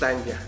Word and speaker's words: Tanya: Tanya, ¿cómo estás, Tanya? ¿Cuál es? ¿Ese? ¿Ese también se oye? – Gracias. – Ah, mira, ese. – Es Tania, bueno, se escribe con Y, ajá, pero es Tanya. – Es Tanya.